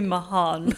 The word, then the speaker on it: Meehan: My name is Mackenzie Meehan. It's actually, it's like Lohan Lohan. So it's actually Meehan.